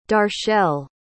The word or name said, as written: darchelle